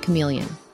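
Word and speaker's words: CM3leon: Meta has recently made an exciting announcement by merging ChatGPT and Midjourney into one powerful model called CM3 Leon, pronounced chameleon.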